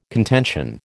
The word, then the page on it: contention: American English